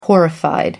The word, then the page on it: horrified: American English